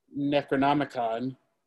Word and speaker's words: Necronomicon: In Jason Goes to Hell, you see the Necronomicon. – Mm-hmm. – Did I say that right? – Yes.